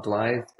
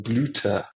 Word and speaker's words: Blythe: but Germans pronounce Y U, so Y is a U, and THE gets pronounced not as like a one syllable, but it gets pronounced as a new. So instead of Blythe, I'm called Bluter, which means flower.